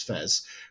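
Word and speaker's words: FES: You've got base Persona 3, Fez, or F-E-S, I think the pronounce is Fez, which is basically Persona 3, but with like this additional chapter at the end.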